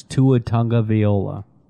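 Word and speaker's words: Tagovailoa: You're an Alabama fan. You should know what it is. Tua Tunga Viola. I think you just mispronounced it.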